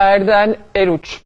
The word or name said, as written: Erden Eruç